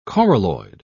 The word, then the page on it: coralloid: British English